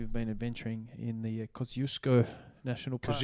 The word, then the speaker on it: Kosciuszko: I know that it's pronounced Kosciuszko.